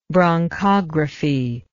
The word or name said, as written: bronchography